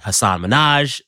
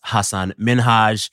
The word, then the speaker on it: Hasan Minhaj: We pronounced his name wrong. We said Hasan Minaj. It should be pronounced Hasan Minhaj.